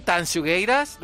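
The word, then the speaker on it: Tanxugueiras: Oye, ¿lo he dicho bien? ¿Tansugueiras? ¿Lo he dicho bien? – Lo has dicho perfecto.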